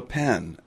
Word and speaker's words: phen: Well, this is called a pen, and it's spelled P-H-E-N.